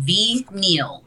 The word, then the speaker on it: Ve Neill: Ve Neill.